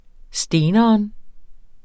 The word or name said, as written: steneren